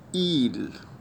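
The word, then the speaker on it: ils: إيل، إيل يعني هو، هنزود لها إس بقى معناها هم، ومنطقش الإس. إيل بس علشان أميزها بينها وبينها وبين المفرد أقول عليها إيل أفيك إس، يعني إيل اللي معاها إس. إيل أفيك إس، بس هي في الجملة بتتنطق إيل زي المفرد.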